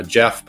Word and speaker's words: Geoff: I'm a Geoff, but it's G-E-O-F-F Roberts.